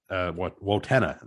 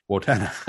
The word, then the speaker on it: Wojtyna: So as crazy as this sounds, my heroes were guys like Wayne Wojtyna. Wotena, now that I know how to say it, Wotena and the Gibbs brothers and Terry Roy.